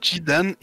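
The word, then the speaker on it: Zidane: Ah, et puis, oh, la scène finale de FF9, quand Jidan. Alors, j'ai jamais su prononcer son nom, donc là, je me base sur ce que tu as dit. – c'est Jidan, et pour euh, anecdote, je crois que.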